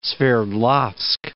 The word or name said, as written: Sverdlovsk